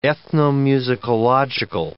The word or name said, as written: ethnomusicological